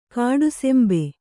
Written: ಕಾಡಸಿಂಬೆ

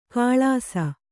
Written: ಕಳಸ

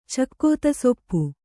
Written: ಚಕ್ಕೊತ್ತಿಸೊಪ್ಪು

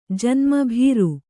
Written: ಭೇರಿ

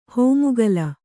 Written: ಹೆಮ್ಮಿಗಿಲು

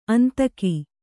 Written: ಅಂದಿಕೊ

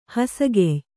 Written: ಹೊಸಗೆಯ್

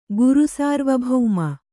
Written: ಗುರುಸಾರ್ವಭೌಮ

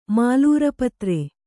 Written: ಮಾಲೂರಪತ್ರೆ

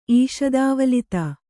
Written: ಈಷದಾವಲಿತ

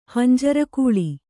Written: ಹಂಜರಗೂಳಿ

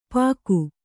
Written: ಪಾಗು

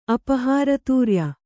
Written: ಅಪಹಾರತೂರ್ಯ

